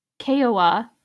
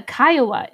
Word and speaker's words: Kiowa: Played by the Kiowa Indian tribe. Played by the Kiowa Indian tribe. I had to look up the pronunciation. It's K-I-O-W-A. And that is the Kiowa Indian tribe of Oklahoma is what they're federally recognized as.